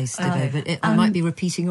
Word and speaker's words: Stibbe: And then I'm also reading how do you pronounce her? Nina Stibbe? – Stibbe. – Yeah, you should say that. I'm never sure how to say that.